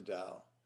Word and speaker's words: Tao: This Tao is spelled T-A-O, and it's pronounced Tao.